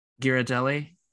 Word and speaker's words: Ghirardelli: Ghirardelli.